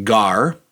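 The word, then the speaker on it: gar (γάρ): It's, it's pronounced gar, but it, it, just, it just means for, F O R.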